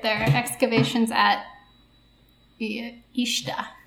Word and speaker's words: Uisneach: Ishta. – Ishta? – I think. It's spelt U-I-S-N-E-A-C-H, but I listened to someone pronounce it, and I'm pretty sure they said Ishta.